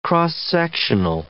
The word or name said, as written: x-sectional